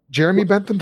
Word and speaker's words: Jeremy Bentham: Jeremy Bentham, did I get his name right? – Yes, you got it.